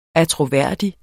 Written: attråværdig

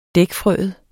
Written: dækfrøet

